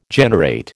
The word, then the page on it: generate: American English